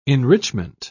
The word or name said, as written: enrichment